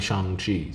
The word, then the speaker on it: Shang-Chi: And he says, "I'm Shang-Chi." So I was like, oh, I've been mispronouncing that this whole time.